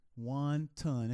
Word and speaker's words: wanton: Wanton. It sounds like I want fried rice with that.